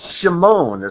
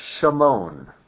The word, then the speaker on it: Shimon: Shimon is how it's pronounced in Hebrew. Shimon.